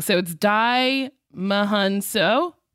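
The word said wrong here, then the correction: Djimon Hounsou: Jaimin Hunsu.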